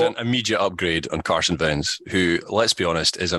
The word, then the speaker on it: Wentz: Carson Wentz is a complete trash bag of a quarterback. – I'm once again begging this podcast to pronounce the W in his name. – Yes, Wentz, command, take command.